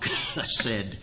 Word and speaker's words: chesed: The Hebrew word is chesed, and I'm not saying that right. Really, there's more of a cough, chesed, in the beginning of the word in order to say it correctly.